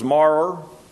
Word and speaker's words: maror: One was maror, I guess is how you pronounce it. M-A-R-O-R, and kazeret.